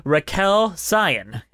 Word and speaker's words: Raquel Cion: Hello and welcome to Musicals with Cheese, and today we got a bonus interview episode with the amazing Raquel Cion.